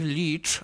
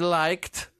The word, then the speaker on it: Leitch: No jedyny plus tej zapowiedzi jest taki, że dowiedziałem się, że nazwisko reżysera czyta się jako David Leitch, a nie jak myślałem David Liked, tylko David Leitch. Tak się czyta nazwisko.